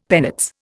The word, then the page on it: penates: Indian